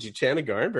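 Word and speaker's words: Jutanugarn: Jutanagarn. – Oh, very good. Jutanagarn, pretty good.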